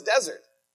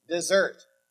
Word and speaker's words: desert: Now, you might look at this word and say, oh, that says desert. It actually is desert. It's spelled the exact same, but the word desert means what they deserve.